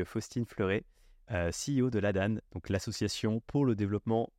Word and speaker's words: ADAN: déjà, est-ce que tu peux te présenter et me dire ce que tu as fait avant l'ADAN ou l'ADAN Je ne sais jamais comment on prononce. – Alors, on accepte les deux. Nous, on va plutôt prononcer l'ADAN.